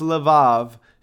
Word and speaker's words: levav: The Hebrew word for heart in this passage is pronounced levav instead of lev. In this usage, there are two bets instead of one. In Hebrew, the B sounds like a V.